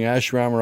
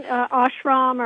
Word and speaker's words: ashram: And first of all, am I pronouncing ashram right? – Yeah, that's fine. Ashram or ashram. That's close enough, yeah.